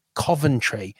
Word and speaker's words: Coventry: For our American listeners, it is pronounced Coventry.